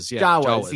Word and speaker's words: Jawas: Jawas.